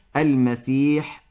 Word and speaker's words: المسيح: أما لفظ المسيح الدجال فينطق بالحاء المهملة، أي من مهملة عن وضع النقط عليها، وليس بالخاء كما يقول بعض العوام المسيح الدجال، فالنطق الصحيح المسيح الدجال.